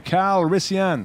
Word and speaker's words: Calrissian: Comment on le prononce, je ne sais jamais. C'est Calrissian.